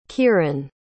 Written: kieron

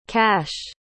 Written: kash

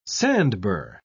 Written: sandbur

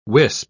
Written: wisp

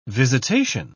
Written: visitation

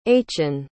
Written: achen

